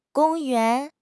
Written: gong1 yuan2

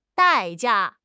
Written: 代价

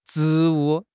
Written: 职务

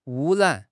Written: wu1 ran3

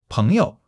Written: 朋友

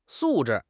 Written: 素质

